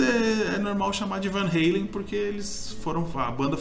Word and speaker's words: Van Halen: Aí tem até uma característica, porque como o Ed e o Alex, que é o irmão dele, baterista, são holandeses, a pronúncia lá na Holanda é Van Halen. Van Halen. Van Halen. Van Halen. E nos Estados Unidos, Van Halen. E no Brasil, Van Halen, né? Que é totalmente errado.